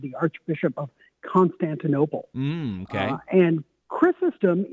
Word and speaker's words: Chrysostom: And I said, I believe it's going to be St. John Chrysanthemum. And she said, I don't think that is the right pronunciation of that. So give me the correct pronunciation, if you would, please. – Yeah, well, of course, I don't speak Greek, so I also will mess this up. But it's St. John Chrysostom.